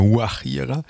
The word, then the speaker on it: Guajira: Und vielleicht zum Wort noch, also das war vom Tanzstil, von der Musikrichtung auch eine Guajira. Also so spricht mit, eigentlich spricht man das eher mit H vorne, Guajira, mit G geschrieben aber.